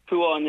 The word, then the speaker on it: Kuan: But, sorry, am I pronouncing it right? Because I know a guy who's C-U-A-N. – C-U-A-N, so first time I've heard of that one. I've met one other Kuan now, right, but... – No way. – Is Kuan how you say it, Kuan? – Kuan, yeah.